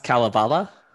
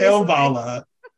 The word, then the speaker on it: Kalevala: It was a custom job by it's pronounced Kalevala. – Okay, that's what I'm guessing. I was going to go with, but I was just like, Kale, maybe. Kalevala Space. – Kalevala.